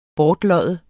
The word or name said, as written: bortlodde